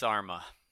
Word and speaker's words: Armah: Armish is how you say his yeah, name? Alex Armah.